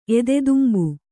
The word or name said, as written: ಎದೆತುಂಬು